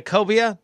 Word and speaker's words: cobia: Any chance of getting a cobia? Is that how you pronounce it? Yeah.